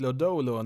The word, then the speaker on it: Lodolo: With the seventh selection of the 2019 MLB draft the Cincinnati Reds select Nick Lodello, a left-handed pitcher from Texas Christian University. – Ja, nu tror jag kommissionären fick lite fel uttal där på Cincinnati Reds första val där. Nick Lodolo, nummer sju totalt i den här draften.